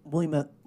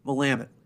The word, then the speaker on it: Willamette: Because the border is the Willamette River, right? – Willamette.